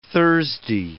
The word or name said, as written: Thursday